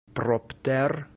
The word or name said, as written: propter